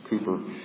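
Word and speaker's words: Cowper: Toplady wrote 6 hymns, William Cooper, that's how his name is pronounced, it's Cooper, 68, John Newton, 280, Philip Doddridge, around 400, Isaac Watts, almost 700, Charles Wesley, 8,989.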